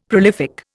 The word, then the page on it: prolific: Indian